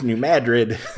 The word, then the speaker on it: New Madrid: It's New Madrid, not New Madrid. – Yes. – That's spelled like, okay. It's spelled like, isn't it spelled like Madrid as in Madrid, Spain? – Yes, but they pronounce it differently.